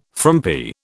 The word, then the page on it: frumpy: British English